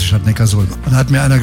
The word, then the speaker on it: Neckarsulm: Habe ich das richtig ausgesprochen? – Nee, ich glaube, das heißt Neckarsulm. – Ja, und warum? – Ja, weil es in der Datenbank steht. – Nee. Und der heißt deshalb Neckarsulm, weil Ulm liegt an der Donau.